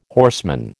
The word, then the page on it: horseman: American English